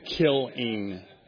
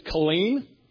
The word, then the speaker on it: Killeen: I was like stressing the kill, like killing. So where are you going? Somewhere named Killing. That doesn't sound good. And then I found out it's Killeen.